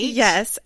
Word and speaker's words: Jadah: It's Jadah rhymes with Prada, right? – Yes, and Nada. Okay.